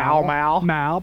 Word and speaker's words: Pall Mall: That's an A. P-A-L-L. How do you say that word? – Pal. – Pal. Okay, pal. How do you say M-A-L-L? Mal. – Mal. – Pal Mal. Pal Mal. – Pal Mal's okay, but how do you get Pell? – Two packs of Pall Mall.